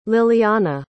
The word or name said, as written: lilianna